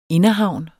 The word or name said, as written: inderhavn